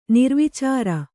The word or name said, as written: ನಿರ್ವಿಚಾರ